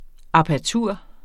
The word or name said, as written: apertur